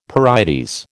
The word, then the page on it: pyrites: American English